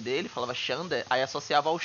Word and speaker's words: Xander: Aí, no, em inglês, a pronúncia do nome dele é, é Xander, né? Parece com Xander, assim.